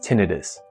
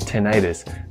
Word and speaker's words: tinnitus: The correct way to pronounce the word is tinnitus. The other way to pronounce the ringing in your ears is tinnitus. That uses the section itis. That typically refers to inflammation like laryngitis, inflammation of the throat.